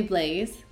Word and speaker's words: Blaise: I say Blaise, okay, because I am not French, but the French way is like bleu.